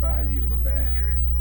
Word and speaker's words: Bayou La Batre: It's spelled B-A-Y-O-U-L-A-B-A-T-R-E. Obviously French. You know what the natives call it? Bayou La Batterie.